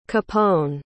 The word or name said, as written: Capone